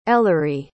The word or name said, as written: ellerie